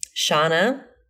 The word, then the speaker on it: Shanna: We got a question from Shanna. She says rhymes with banana. Thank you. See, I would have said it wrong.